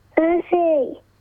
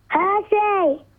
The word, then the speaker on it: Percy: Percy. – What's your name? – Percy. – One more time. – Hershey. – Tell, how do you spell that? Sounds like you're saying Hershey. – P-E-R-C-Y.